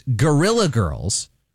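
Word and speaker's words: Guerrilla Girls: The internationally recognized Gorilla Girls bring their art exhibition to Fresno State. Now, it's spelled G-U-E-R-R-I-L-L-A, like guerrilla, like guerrilla warfare.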